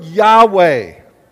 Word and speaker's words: Yahweh: The word Yahweh is how a Hebrew in most Hebrews today would pronounce, if they were to pronounce God's name, it would sound something like Yahweh.